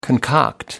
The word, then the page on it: concoct: American English